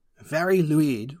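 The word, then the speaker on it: Fari Lwyd: Very Luid, which is Welsh, so that is in no way spelled like it's pronounced.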